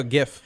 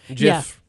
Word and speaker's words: GIF: Oh, GIF. GIF. – Yeah. – Sorry. Soft G.